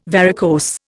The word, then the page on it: varicose: Indian